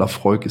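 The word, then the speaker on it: Laphroaig: eher, so eher so Laf, Laf, wie wird er ausgesprochen? Laf, Lafroy? Lafroig, glaube ich, glaube ich. Ich habe immer Lafroig gesagt, aber ich glaube, Lafroig ist tatsächlich die Aussprache.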